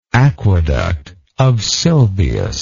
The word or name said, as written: aqueduct of Sylvius